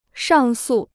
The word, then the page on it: 上诉: shàng sù